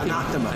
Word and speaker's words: Anathema: Dice, no, es Anathema.